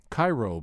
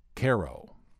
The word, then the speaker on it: Cairo: This first tune looks like it should be pronounced Cairo Blues after the city in Egypt, but this is not the same town. This is on the Mississippi River in southern Illinois, and there they pronounce it Cairo.